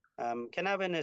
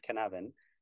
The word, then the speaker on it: Cynefin: Canavan is C-Y, pronounced and spelled C-Y-N-E-F-I-N. So pronounce it how you like, but he likes to call it Canavan.